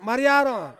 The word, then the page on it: mariaron: Ansus